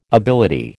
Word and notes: ability: American English

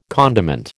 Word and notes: condiment: American English

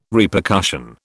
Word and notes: Repercussion: British English

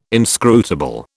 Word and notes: inscrutable: British English

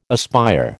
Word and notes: aspire: American English

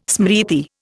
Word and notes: smriti: Indian

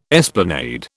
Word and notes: esplanade: British English

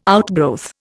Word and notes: outgrowth: Indian

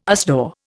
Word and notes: USDAW: Indian

